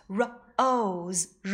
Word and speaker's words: rose: 原来呀，它们都不发音。 Lake l a k e lake bike b i k e bike rose r o s e rose Kate k a t e Kate 这个 e 呀，我们也叫做哑音 e。